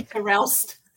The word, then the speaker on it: Terelst: Terelst.